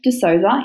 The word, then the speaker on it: DeSousa: I have Steph D'Souza in the office with me. Welcome, Steph. – Thank you. – Did I say your name right? – Yeah. – Yes. So if people want to find you, it's Steph and then D-E-S-O-U-S-A.